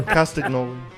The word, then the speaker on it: Castagnoli: Thank you for putting Cesaro down and not Claudio Postagnoli or whatever. – Castagnoli.